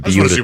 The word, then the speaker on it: Buda: Well, B-U-D-A? – Can you yes. get someone on the phone from Beulah, Texas, please? – Yeah, B-U-D-A.